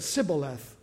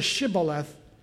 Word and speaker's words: Shibboleth: But the Ephraimites couldn't say that correctly. They said Sibboleth instead of Shibboleth.